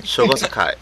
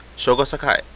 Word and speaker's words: Shogo Sakai: Shogi Sakana? I don't know. – Shogo Sakai. – You know how to say it. How do you say it? – Shogo Sakai.